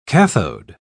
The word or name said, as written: cathode